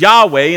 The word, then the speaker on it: Yahweh: See, the name of the Lord is Yahweh in the way that we would pronounce it, and it's saying something about his character.